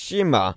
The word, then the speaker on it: Shikishima: Shikish Shikishima.